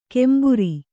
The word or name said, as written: ಕೆಂಬುರಿ